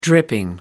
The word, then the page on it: dripping: American English